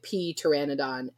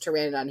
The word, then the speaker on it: pteranodon: Sammy and I were reading a book that had a pteranodon in it, and he was like calling it pee pteranodon. And I was like, it's just pteranodon. He's like, but there's a pee. And I was like, the pee is silent.